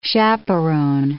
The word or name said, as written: chaperone